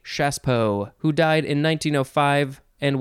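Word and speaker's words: Chassepot: It's spelled C-H-A-S-S-E-P-O-T. C-H-A-S-S-E-P-O-T. It looks like chasspot, but you say Chaspo.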